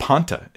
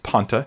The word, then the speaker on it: panta: That's that word panta. That's how it's pronounced, panta. In the Greek, the P, alpha, nu, tau, alpha, that's that word right there.